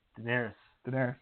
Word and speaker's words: Daenerys: Daenerys.